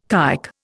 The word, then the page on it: kike: Indian